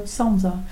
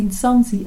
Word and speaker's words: Nsanzi: And so he thought he heard Sansa. And it is still in use, Sansa, but it should be Nsanzi, and it shouldn't even be Nsanzi, because Sansa is a word used for all lamellophones, but they all have different names.